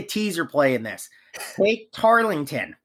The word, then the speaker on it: Tarleton: Tarleton.